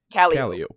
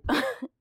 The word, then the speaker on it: Calliope: So we have Cat we have a street that is spelled Calliope, but it is pronounced Calliope. – Calliope.